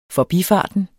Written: forbifarten

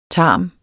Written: tarm

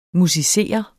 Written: musicere

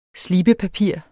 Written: slibepapir

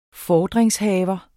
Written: FORDRINGSHAVERE

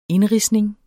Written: INDRIDSNING

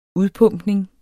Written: udpumpning

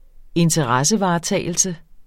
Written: interessevaretagelse

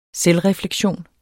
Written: selvrefleksion

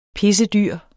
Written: pissedyr

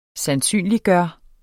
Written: sandsynliggøre

